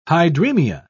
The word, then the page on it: hydremia: British English